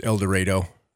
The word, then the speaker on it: El Dorado: The town of El Dorado is pronounced El Dorado.